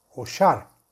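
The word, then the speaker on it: Oshar (ושר): y deberá meditar el santo nombre de Hashem que se escribe Vaf Shin Reish, que se pronuncia Oshar,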